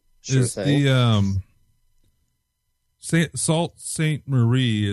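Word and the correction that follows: Sault Sainte Marie: is the Michigan Paracon you go to, right? – Right, Sioux Saint Marie. – Yeah. Oh, okay. They must have had it pronounced wrong, but it's the seventh annual MI Paracon. Yeah, it looks like Salt.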